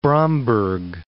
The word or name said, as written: Bromberg